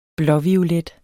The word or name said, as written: blåviolet